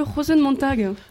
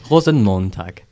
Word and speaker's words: Rosenmontag: Et alors qu'est-ce que le Rosenmontag ? – Non, s'il te plaît, fais gaffe à ta prononciation. – Pardon, excuse-moi. – Rosenmontag.